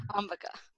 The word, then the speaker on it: Ambika: Yes, Ambika.